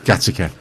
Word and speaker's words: Gateacre: Gateacre, yeah. It's pronounced Gattaca.